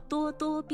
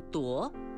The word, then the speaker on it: 咄: 多多也是叹词，表示感叹或惊诧，形容气势汹汹，盛气凌人，叫咄咄逼人。使人非常惊讶的是叫咄咄怪事。多只有一种读音，请不要读成夺，说咄咄逼人、咄咄怪事是不正确的。